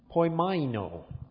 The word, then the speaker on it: poimaino: The next one that's translated rule is the word poimaino. Poimaino. Poimaino. Poimaino. That's what my little pronunciation guide says.